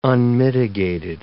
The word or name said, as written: unmitigated